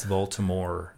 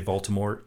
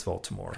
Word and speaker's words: Voldemort: It's French, so the T is silent. So, but in the movie, there's some of the people that say Voldemort, because that was written on the script. But it's Voldemort.